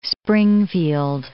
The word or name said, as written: Springfield